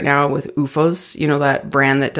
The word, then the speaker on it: OOFOS: It's like they have, it's spelled OOFOS. – Recovery footwear? – Yeah. It's UFOS is a recovery footwear brand.